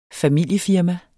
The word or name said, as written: familiefirma